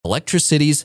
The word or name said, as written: Electricities